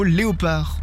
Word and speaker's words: léopard: Quelle lettre ne se prononce pas dans le mot léopard? – Le D.